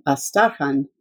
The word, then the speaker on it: Astrakhan: Plus, she was from Astrakhan, which we all pronounce the old way, Astarhan, and she belonged to the same clan as we did.